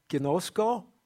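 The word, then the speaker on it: ginosko (γινώσκω): If not, it's pronounced ginosko.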